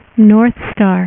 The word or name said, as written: northstar